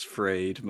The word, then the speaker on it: Fried: You know, Surely it's freed, man. Freed, sorry, yeah.